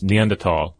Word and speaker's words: Neanderthal: Yeah, the Neanderthal, which is typically spelled, I mean, it's spelled like it's Neanderthal, and I've been saying it wrong for ages, but of course, as you pointed out, it comes from the German, so it's Neanderthal.